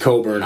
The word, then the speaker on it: Cockburn: And then also Kofi Cockburn. – It's Coburn. – Coburn. – It's spelled Cockburn, but it's pronounced Coburn.